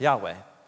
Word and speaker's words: Yahweh: or in Hebrew pronounced Yahweh.